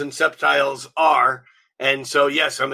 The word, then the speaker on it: septiles: Also in February from Rick, we're going to be having the long awaited septiles and quintiles class.